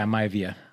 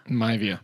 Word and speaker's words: Maivia: How do you say the name? – Or Great Chief Maivia. – Maivia? – Yeah, Maivia. – Maivia.